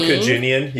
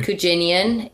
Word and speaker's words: Kujinian: Saman is joining us for the next episode to talk about Saman Kujinian. You can say his name. – Kujinian. – Yeah. – Like Virginian, but without the V or the R.